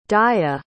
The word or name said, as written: daia